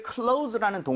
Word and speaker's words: close: 자, 우리 닫는 것을 close라는 동사로 썼습니다. 그렇죠? – 네. – S는 Z 비슷하게 발음을 해서 close가 되는데 자, 이거 나온 김에 여러분들 같이 항상 여러분 교재에 필기해 주세요.